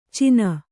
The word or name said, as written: ಚೀನ